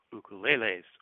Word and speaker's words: ukulele: ukuleles or ukuleles. Correctly pronounced ukulele, it means jumping flea in Hawaiian, and in regards to electric ukuleles, yes, there are.